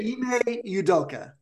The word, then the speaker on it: Ime Udoka: So Ime Udoka, right? – Ime Udoka.